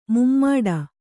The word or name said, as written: ಮೂಮಡಿ